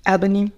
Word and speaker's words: Albany: Habe ich das richtig ausgesprochen, Albany? Albany, Albany. ja.